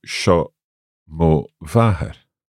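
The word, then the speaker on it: seo mo mhàthair: So could we say sha mo vahar? – Sha mo vahar. – Vahar, okay. – Yes, with, long a nice, a. with a nice long A.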